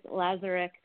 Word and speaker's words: Lazareck: Pretty close. It's Lazarek.